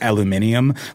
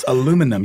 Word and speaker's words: aluminium: Sort of like aluminium. Like, that's not the word, Brits. Come on. It's aluminum. You're adding syllables in there that don't belong.